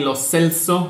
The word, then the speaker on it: Lo Celso: Respectively, the midfielders are Guido Rodriguez of America de Mexico, Giovanni Lo Celso, apparently is how he pronounces it since he moved to England, so we'll take that.